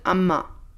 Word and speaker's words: Amager: And Amma is written A-M-A-G-E-R. – Yeah. – Like Amager. And you pronounce it Amma. Mm-hmm. A-M-A.